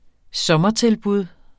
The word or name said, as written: sommertilbud